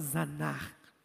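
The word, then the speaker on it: zanh: Agora nós estamos vendo outra palavra que chama Zanar. O que é isso? Z-A, você que está anotando, Z-A-N-H. Z-A-N-H, Zanar. Para nós que somos brasileiros.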